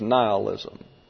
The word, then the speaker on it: nihilism: I think the correct pronunciation is nihilism.